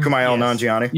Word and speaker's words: Kumail Nanjiani: Kumail Nanjiani.